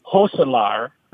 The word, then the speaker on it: Gosselaar: He said, in fact, the way you pronounce my last name is Gosselaar. It's Dutch.